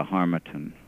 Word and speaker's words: Harmattan: Uh, we learn a great deal uh, in this book about something called, am I pronouncing it correctly, the Harmattan yeah, Harmattan. The Harmattan.